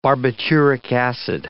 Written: barbituric acid

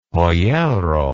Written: pollero

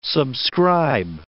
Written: subscribe

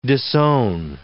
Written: disown

